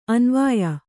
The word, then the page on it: ಅನ್ವಯ: anvaya; Kannada